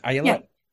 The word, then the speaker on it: Ayelet: Ayala, did I say it right this time? Ayala, yeah.